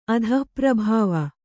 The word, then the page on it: ಅಧಃಪ್ರಭಾವ: adhahprabhāva